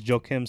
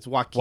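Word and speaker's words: Joakim: Fact check: the third head of that three-headed beast in the bullpen was Joaquin Soria. – Joaquin. – It's Joaquin. – Joaquin, sorry. Joaquin.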